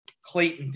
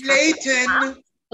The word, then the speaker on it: Clayton: they pronounce the T very emphatically and they say Clayton. And that, I think, is just something that will irritate me the entire season. I'll just Clayton.